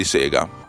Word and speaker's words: Sega: 9,99$ per un gioco della Sega, che si pronuncia Sega, ricordatevi, perché Sega sta per Service Games, ok? Non sta per Sega, è giapponese.